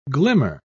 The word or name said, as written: glimmer